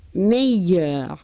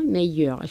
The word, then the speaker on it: meilleur: Y lo mismo con EI. Meilleur, ¿eh? Meilleur. Suena perfectamente la doble L, como ven.